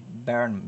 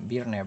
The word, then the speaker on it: Byrne: Eh, David Byrne, Byrne, Byrne, como lo pronuncian, el de los Talking Heads.